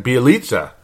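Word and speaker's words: Bjelica: Well, because Sam Mitchell wanted to play Nemanja, now that I can finally say it right, Bielitsa. 22 minutes in the game. The whole fourth quarter, the guy played like shit. Pardon my French, because I try to keep this a family show, but Bielitsa.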